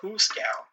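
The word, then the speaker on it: hoosegow: Why do people call jail the Hooskow? – Piggies and the Hooskow.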